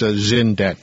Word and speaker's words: Xyndet: There's a product line, XYNDET. XYNDET, pronounced Zindet.